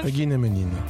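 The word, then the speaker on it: Huginn & Muninn: Vous avez aussi écrit plusieurs livres comme Super-héros, deux points, une histoire française aux éditions Eugene Munin, je ne sais pas comment on prononce. – Eugene Munin, d'accord.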